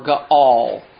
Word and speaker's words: Gaal: So now we have a turning of allegiance from Abimelech to this gentleman named Gaal, or Gaal, might be a better pronunciation.